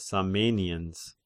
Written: Samenians

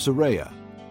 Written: Seraiah

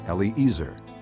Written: Eliezer

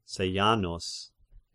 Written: Sejanus

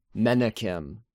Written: Menahem

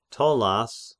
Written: Ptollas

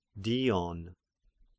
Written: Dion

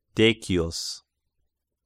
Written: Decius

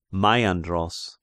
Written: Maiandros

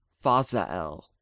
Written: Fazael

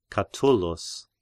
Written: Catullus

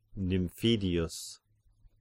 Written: Nymphidius

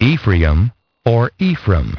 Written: Ephraim

